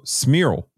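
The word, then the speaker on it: Cmíral: His name is Eli Cimiral. Cimiral. I apologize for, oh, Smearle. That's how you pronounce it.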